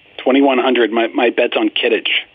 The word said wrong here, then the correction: Quidditch: Quidditch.